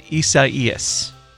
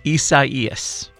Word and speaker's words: Isaias: But according to people who know, it is pronounced Isaias. So say it with me. Isaias.